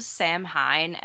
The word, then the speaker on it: Samhain: Yeah, so it is spelled Samhain, S-A-M-H-A-I-N. – Yes. Okay, right. I did know. – Samhain. – Yeah, I guess I did know. – So if you've ever read about it, then you know, but if you've never heard it pronounced, you probably didn't.